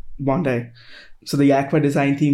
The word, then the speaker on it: Bondi: Is that Bondi? Is that how you pronounce that? – Yeah. Yeah, it's from the iMac, the iMac color.